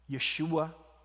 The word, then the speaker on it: Yeshua: It's how the Jews translated the Old Testament name Joshua or Jehoshua, Yeshua in the Hebrew pronunciation. Now, there is no sh sound, sh sound in Greek. So that sh became an s.